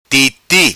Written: Titi'

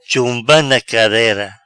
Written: Chumba na carera

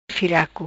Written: Firaco

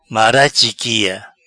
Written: Mara chiquia

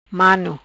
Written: Mano

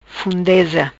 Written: Fundeza

